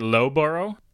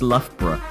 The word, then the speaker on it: Loughborough: That's that's, is it Lowboro? – It's not. I was expecting you to go Luga Baruga. – I'm sorry. Let me try it again. Is that pronounced, I believe it's Luga Baruga? – Yeah, no, it's Loughborough.